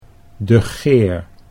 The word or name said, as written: De Geer